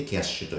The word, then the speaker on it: KYASH: な状況を今先に伝えておくとクレジットカード 2 種類使ってましてはいメインはキャッシュっていうプリペイド式クレジットカードを使ってます。 – えー、キャッシュはですね、 KYASH と書いてキャッシュと読ませる、そういう新しめのサービスなんですけど、はい。